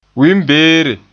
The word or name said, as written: wiinbeere